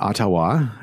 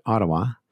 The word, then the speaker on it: Ottawa: And not Ottawa, as some people like to pronounce it, but Ottawa. That's how the Canadians pronounce it.